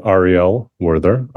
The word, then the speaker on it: Ariel Werther: My name is Ariel Werther. Ariel spelled A-R-I-E-L. Werther spelled W-E-R-T-H-E-R.